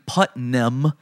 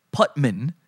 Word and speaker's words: Putman: His name is not Jeremy Putnam. It's Jeremy Putman. Putman. Now, she called Batman Batman, but she couldn't say Putman.